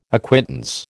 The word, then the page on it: acquittance: American English